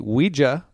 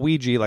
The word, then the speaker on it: Ouija: I'm going to say it correctly too. The Ouija board. Not Ouija, like I've always said since I was a little kid.